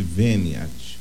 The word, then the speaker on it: venia: A reverência é aquela leve inclinação que chama-se veniat. Venia, né? Se escreve veniat, mas é venia.